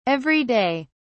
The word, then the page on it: every day: British English